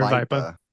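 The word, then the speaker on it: Aravaipa: So I had been like, first of all, is it Aravipa or Aravapa? – How do you pronounce it? Aravipa.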